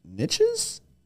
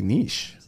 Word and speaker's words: niche: And we came up with the idea of doing weird real estate niches? – I say niche. – Gosh, you're so fancy. – Yeah, and I really lean into the E. It's like a long E, like niche.